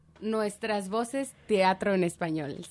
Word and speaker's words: Nuestras Voces Teatro en Español: Did I say that right? – I'm not, I don't have a great accent. Let's hear you say it. – Nuestras Voces Teatro en Español, sí. – Sí. Awesome. – To say it correctly.